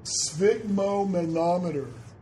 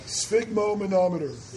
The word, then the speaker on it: sphygmomanometer: So everyone focus, sphygmomanometer. One more time, sphygmomanometer.